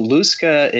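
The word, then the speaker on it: lusca: So Luska, and you are pronouncing it right, at least as far as I know. That's how I would pronounce it.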